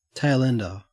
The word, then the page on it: tailender: Australian English